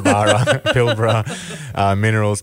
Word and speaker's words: Pilbara Minerals: Pilbara Minerals, well-known company. – Pilbara. Let's get some pronunciation. – Pilbara Minerals.